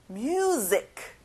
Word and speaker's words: music: One more time. 뮤직이 아니라 music.